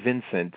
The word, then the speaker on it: Vincente: I have to ask you, Vincent or Vincente? – It's actually Vincent. I've heard it pronounced either way, but most typically you hear Vincent Minnelli is, I think, the correct pronunciation.